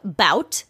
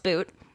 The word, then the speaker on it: Bout: So it's spelled bout. So if I accidentally say bout at any point, that's why, but I guess it's pronounced boot.